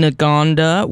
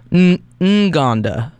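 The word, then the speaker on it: Ngonda: Before that, we heard from Jalen Nagonda, which apparently it's like the pronunciation is kind of in between what I said before. It's sort of Nganda, so it's like a half of an N.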